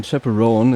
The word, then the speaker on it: Chappell Roan: Pink Pony Club von Chappell Roan.